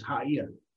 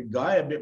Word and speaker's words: Gaia: It's not Gaia, it's Haia, because you pronounce the G in the old language as an H.